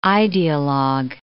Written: idealogue

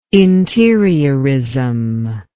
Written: interiorism

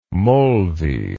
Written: molvi

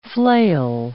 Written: flail

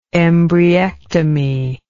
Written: embryectomy

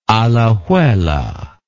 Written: Alajuela

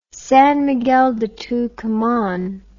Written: San Miguel de Tucuman

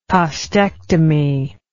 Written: costectomy

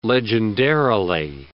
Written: legendarily

